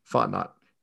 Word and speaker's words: Fontenot: I don't know how to say his name. Fontenot? – Mm-hmm. – Okay. Fontenot. The new general manager of the Falcons.